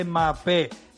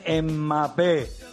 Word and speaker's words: Mbappé: Mbappé no se dice Mbappé, se dice Mbappé.